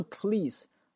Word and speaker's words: police: 我们首先来看发音部分，But T 不发音，police 警察，很多学生读成 police，它不是 police，它是 police。